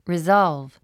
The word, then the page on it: resolve: rizάlv; リゾーゥブ